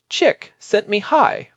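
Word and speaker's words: Csikszentmihalyi: Pronounced chick sent me high, for the record.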